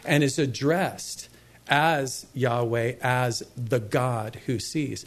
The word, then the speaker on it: Yahweh: which is pronounced most popularly Jehovah, probably most accurately Yahweh.